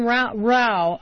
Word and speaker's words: Rau: And I've also got John Rau, rhymes with cow, president and founder of Americans for Campaign Reform.